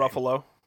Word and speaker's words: Ruffalo: Mark Ruffalo, or Ruffalo, I don't know how you say his name. – Ruffalo.